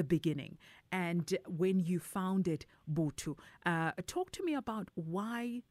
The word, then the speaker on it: Vhuthu: It's spelled V-H-U-T-H-U culture. How do we pronounce it, Tandega? – It's actually Bootu. Bootu. – It's in Tshivenda.